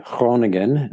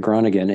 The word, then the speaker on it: Groningen: I ended up, there was a team in the northern town called Groningen, is how you pronounce it, Groningen in the Netherlands, that their goalie had just left.